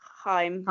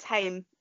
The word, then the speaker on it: Haim: Anyway, we love Taylor and we love, I always say it wrong, Haim. – Like Hymen. – Yeah, but I think I always think it can't be that. It's Haim.